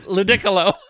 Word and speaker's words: Ludicolo: ludicolo. Ludicolo. – There's it's like the, piccolo, but with a lude in front of it.